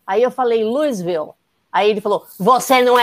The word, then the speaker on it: Louisville: você é de Louisville, você pronuncia Louisville.